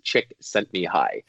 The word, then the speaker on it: Csikszentmihalyi: And there's a phonetic pronunciation I found on the internet that literally in quotes says "Chick sent me high" to pronounce his last name, but it doesn't look like that at all if you were to read it.